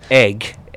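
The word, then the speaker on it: Eigg: I want to say Egg. – It's Egg. Isle of Egg Trust.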